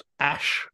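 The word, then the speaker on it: Æ: The full name is X, and then an AE diphthong, which is apparently pronounced ash.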